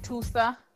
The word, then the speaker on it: Tusa: I don't know if it's called pronounced Tusa. – Yes, I think that's how you pronounce it.